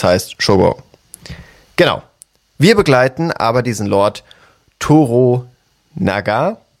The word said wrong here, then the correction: Toranaga: Toranaga, Toranaga, genau. So ist es ausgesprochen, richtig.